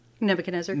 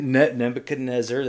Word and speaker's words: Nebuchadnezzar: Nebuchadnezzar. – Nebuchadnezzar, there you go.